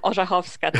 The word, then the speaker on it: Orzechowska: Yes, that was close enough. Orzechowska, to be exact.